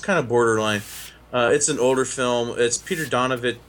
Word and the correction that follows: Bogdanovich: Bogdanovich's first movie.